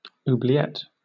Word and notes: oubliette: British English

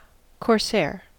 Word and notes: corsair: American English

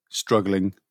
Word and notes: struggling: British English

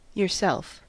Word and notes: yourself: American English

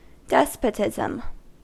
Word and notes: despotism: American English

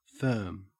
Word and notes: firm: Australian English

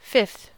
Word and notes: fifth: American English

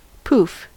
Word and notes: pouf: American English

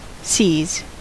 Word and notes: seas: American English

